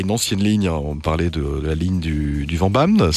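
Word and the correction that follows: Vennbahn: c'est ça? – De la Fennbahn, oui, la tout Fennbahn, à fait. Fennbahn, oui, pardon, ça prononce comme ça.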